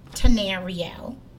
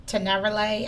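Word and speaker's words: Tanerelle: For me, as far as love songs, the artist's name is Tenariel. It took me forever to figure out how to pronounce her name, because I thought it was Tenerele. I don't know. It's T-A-N-E-R-E-L-L-E.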